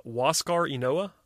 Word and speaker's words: Huascar Ynoa: Um, and I'm going to try to pronounce his name right now. It's uh, Huascar Ynoa. That's what I'm going with. That's what the pronunciation what the pronunciation guide says.